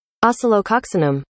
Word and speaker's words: Oscillococcinum: This is the Google pronunciation. I'll put it on the... – Oscillococcinum.